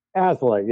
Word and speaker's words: Aslay: Jonathon, how do you say your last name? Is it Aslay? – Aslay, yeah. – Aslay. Okay, so I got it right.